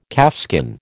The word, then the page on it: calfskin: American English